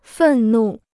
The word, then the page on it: 愤怒: fèn nù